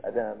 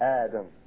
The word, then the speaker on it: Adam: It's not pronounced Adam. It's Adam, alayhi salam.